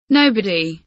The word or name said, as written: nobody